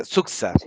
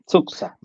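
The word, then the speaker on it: Xuxa: Y se pronuncia Suxa. Sí, Suxa.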